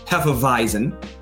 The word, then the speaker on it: Hefeweizen: Yes, pronounced Hefeweizen.